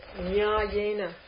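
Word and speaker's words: nyāyena: Look at the next word, nyayena. You should see a long ah there.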